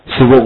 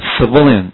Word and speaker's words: civilian: 第二叫国内的，国内战争叫做 Civil war。最后加上 on 变成人嘛，所以重音不再念成 civil，而变成 v 的前面叫做 civilian，清楚了吧？